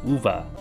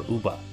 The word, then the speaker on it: Uva: If you have Violet, it's Uva or Uba Academy. I want to say that these are probably the Spanish pronunciations, where the V is pronounced like a B and the J is pronounced like an H, because it's pretty obvious that this region is based on Spain.